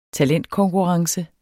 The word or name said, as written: talentkonkurrence